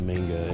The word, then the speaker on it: manga: I hate pronouncing it like that. I know it's the right way to pronounce it, but I grew up pronouncing it manga,